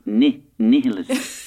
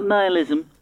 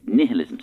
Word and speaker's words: nihilism: And first things first, the pronunciation is nihilism. Nihilism. Not nihilism and not nihilism. It's nihilism.